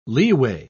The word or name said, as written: leeway